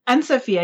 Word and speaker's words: Anne-Sophie: Anne-Sophie, I do use both.